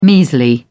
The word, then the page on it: measly: British English